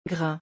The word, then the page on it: grain: French